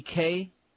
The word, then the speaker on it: Kaye: Was it Danny Kaye? Rhymes with Faye.